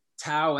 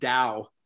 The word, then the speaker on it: Tao: Um, pronounced, actually pronounced Dao, but I want people to be able to look it up. So I'm going to let people make fun of me for mispronouncing it.